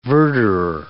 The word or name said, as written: verderer